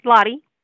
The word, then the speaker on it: Lodde: LODDE.